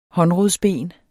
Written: håndrodsben

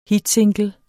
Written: hitsingle

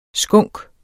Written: skunk

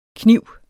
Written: Knive

